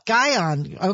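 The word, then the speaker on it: Gihon: I hope I got that correct. Lee Cattell helped me this morning because I didn't know how to pronounce it. – Gaihan.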